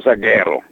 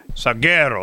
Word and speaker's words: Salguero: Certainly of the good guys like Fernando Antonio Salguero. You know, am I saying that right? – Yeah, man. – All right. – Fernando Antonio Salguero. – Salguero. Oh, that sounds very cool.